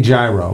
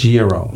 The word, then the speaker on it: gyro: Yeah, because you don't say gyro, right? It's gyro.